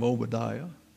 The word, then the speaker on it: Obadiah: So if you're having a child and you're wondering what you can name him, you can think of Obadiah. The accent is on the I, Obadiah.